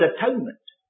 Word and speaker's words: atonement: It's incipient in the word atonement. As you know, the old English word atonement used to be pronounced at one-ment.